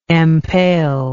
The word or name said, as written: empale